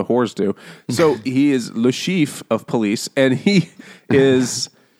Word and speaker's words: Le Chiffre: How do you say that really French? – Le Chiffre. – Le Chiffre? – Le Chiffre. – The R at the end is like so slight and so like just back of the throat.